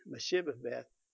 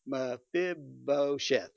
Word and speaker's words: Mephibosheth: He later on took Jonathan's son, Mephibosheth, I'll say it right one day, Mephibosheth, thank you.